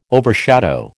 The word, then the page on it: overshadow: American English